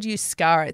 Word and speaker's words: Euskara: It's actually, it's called Euskara.